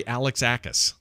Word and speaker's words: Alexakis: It's, Art Alexakis, who's the lead singer of the band Everclear. – That's literally Alexakis. – Alexakis. – Ah, shit. – Just like it's spelled.